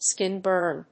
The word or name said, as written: skin burn